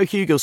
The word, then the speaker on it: Hugill: Joe Hugill.